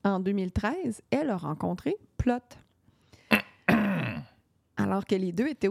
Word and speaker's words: Plote: Ça s'écrit P-L-O-T-E. On ne le prononce pas comme ça en anglais, mais moi, je l'appelle Plott.